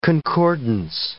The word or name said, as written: concordance